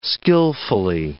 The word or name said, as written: skillfully